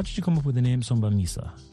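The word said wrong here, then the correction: Sobremesa: Okay, so Sombremesa. – Sombremesa, oh, thank you very much. That is a Spanish pronunciation? – Yeah, it is.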